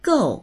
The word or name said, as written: gou4